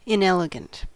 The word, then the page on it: inelegant: American English